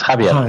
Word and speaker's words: Javier: Yes, Javier.